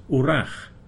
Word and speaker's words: hwyrach: He also uses the word hwyrach, pronounced wrach, which means perhaps.